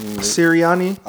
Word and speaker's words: Sirianni: Sirianni.